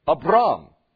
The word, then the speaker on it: Abram: Abram is really how it's pronounced in the Hebrew before his name becomes Abraham, but Abram.